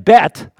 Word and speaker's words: Bet: And then Bet, I remember that one, they don't pronounce the H.